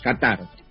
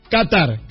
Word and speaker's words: Qatar: Qatar. – Qatar, Qatar, así como suena.